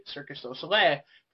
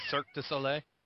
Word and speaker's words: Cirque du Soleil: It's the type of music you'd hear at a circus show, especially a French circus, aka Circus du Soleil, for those who don't know. Because apparently, though, my mom probably wants to storm in and say, it's said like this, because apparently I've been saying it wrong all these years. – Well, Cirque du Soleil? – Yeah.